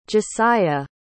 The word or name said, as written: jasiah